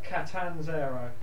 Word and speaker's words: Catanzaro: Catanzaro.